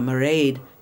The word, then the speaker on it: Mairéad: You know, or a Mairéad. Nguyen, I think I said that right. – That's very good.